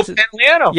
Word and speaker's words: Pantoliano: Joe Pantoliano.